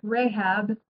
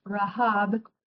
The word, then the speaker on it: Rahab: Some people call her Rahab. Her name is pronounced Rahab, who helped the Israelites at Jericho when Joshua sent the two spies to the land, Canaan.